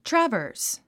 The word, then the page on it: traverse: trəvə́ː r s; トラバース